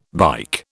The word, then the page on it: bike: British English